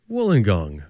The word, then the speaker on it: Wollongong: You left from a place called Wollongong. – not bad. Yeah, Wollongong. Yeah, Wollongong. – Wollongong. – Wollongong. – Wollongong. Of course. – Yeah, you got it.